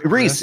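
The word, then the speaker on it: Vriess: Reese. It's just Reese.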